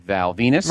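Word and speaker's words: Val Venis: And that is, of course, Mr. Val Venus. – Rhymes with penis. – Which he does not say. – Hmm. – Rhymes with penis. He does not want it to.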